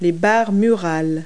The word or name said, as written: wall bars